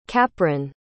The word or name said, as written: Capron